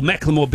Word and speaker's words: Macklemore: The Ed Sheeran controversy around him removing Macklemore, or Macklemore being removed from the Ed Sheeran tour, is the correct way to say it, is not going away.